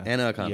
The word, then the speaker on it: Anna Akana: How do you exactly pronounce it? Is it Anna? – Anna Akana. – Yeah. Not Anna Akana. Anna. Yeah.